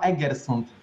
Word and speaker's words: Egersund: E, wiesz co, tak w ramach sprostowania moje miasto się nazywa Egersund. Czy zastanawiam to, to. – Właśnie, bo... – Tak jak pisze się, tak jak mówi.